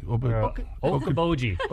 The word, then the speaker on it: Okoboji: Okoboji. – Okoboji.